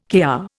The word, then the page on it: Kr: Indian